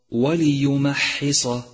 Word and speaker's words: وليمحص: أولا تأمل كلمة وليمحص وليمحص واضبطها بالشكل الصحيح، فانتبه لكسر اللام ولترقيق الميم ولهمس الحاء وتفخيم الصاد، والفظها بشكل صحيح هكذا وليمحص وليمحص.